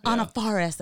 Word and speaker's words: Anna Faris: Anna Faris, as she pronounces it, I believe.